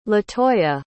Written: latoya

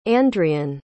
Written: Andrian

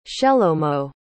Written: shelomo

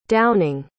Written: downing

